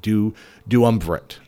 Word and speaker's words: duumvirate: Dumerative. I'm screwing it up. It's such a unique word. Duumvirate. That's it. Duumvirate. You try to spell it. You try to do it. It's D-U-U-M-V. I-R-A-T-E, duumvirate.